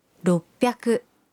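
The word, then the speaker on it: ropyaku (ろっぴゃく): 600 se convierte en ropyaku.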